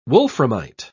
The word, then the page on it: wolframite: British English